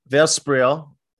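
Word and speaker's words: Versprille: Author Allison Verspral, let me see how to spell that, say that. Allison Verspral, that's how you say it.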